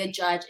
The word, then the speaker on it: Adjadj: if, first of all say a judge it doesn't it doesn't it's not spelled like a judge it's like adj adj